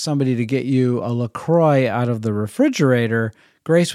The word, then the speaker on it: LaCroix: By spelling, and it's spelled capital L-A, capital C-R-O-I-X. So, I don't know how you say this, but my daughter Grace loved to pronounce it Lachronix. And in our family, when I read C-R-O-I-X, and it's prefaced by La, I would pronounce it LaCroix. So I do believe that is the correct pronunciation, and I've heard it pronounced by many, many other people that way.